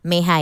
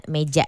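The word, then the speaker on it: Mejía: Ya bacanya tulisannya meja tapi bacanya kayaknya Meha ya, bukan meja ya.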